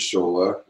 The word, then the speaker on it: Shola: And his name is Shola, rhymes with Cola, rhymes in between.